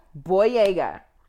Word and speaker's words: Boyega: Boyega? – Boyega.